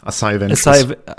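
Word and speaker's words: Asajj Ventress: Man kann jetzt mit Kit Fisto und Asha Ventress, weil ich wahrscheinlich falsch ausgesprochen habe, aber wie wird's ausgesprochen? – Also ich sag immer Asai. Asai Ventress. Asai Ventress.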